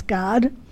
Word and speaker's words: Gad: Now, Gad actually means, or Gad you might call it, but is it pronounced, correct pronunciation is Gad, means a troop.